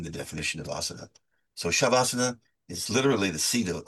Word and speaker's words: Shavasana: Shavasana. And am I saying it correctly? – You are saying it correctly. Shavasana is a Sanskrit word, which consists of two smaller words.